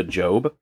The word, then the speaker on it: Job: Wait, Job, is that's how you pronounce that? I always thought it was Job. – It's not Job. No, it's Job.